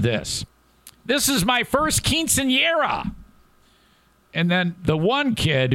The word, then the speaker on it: quinceañera: And the little girl goes, it's a quinceañera. I go, oh, okay. So I got to say it like the way you say it. I have to say quinceañera.